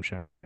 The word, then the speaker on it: Schenk: Yeah, S-C-H-E-N-K. – Yeah, no, it's pronounced Adam Shank.